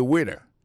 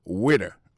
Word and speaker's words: waiter: I am a waiter. Ça veut dire que je suis un garçon, je m'observe dans le restaurant. Alors, moi, je prononce le waiter. Waiter, ça veut dire accent tombé sous le premier syllabe là. Waiter, W-A-I-T-E-R.